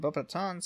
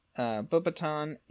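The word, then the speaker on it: Beauxbatons: In the film adaptation of Harry Potter and the Goblet of Fire, Bobaton is incorrectly pronounced by Dumbledore as Bobaton. Bobatons. This appears to be an error in the pronunciation on Dumbledore's behalf, seeing as the French students pronounce it correctly.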